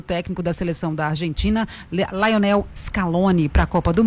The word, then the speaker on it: Lionel: É, agora você falou o Lionel errado. Não é Lionel. – Lionel. – É Lionel. Lionel Messi.